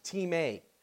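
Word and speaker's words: time (τιμή): It's pronounced time, and it's actually spelled time, T-I-M-E, but it's pronounced time, which means value.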